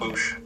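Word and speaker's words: Boushh: Boosh.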